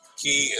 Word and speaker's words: Cay: C-A-Y is pronounced key properly.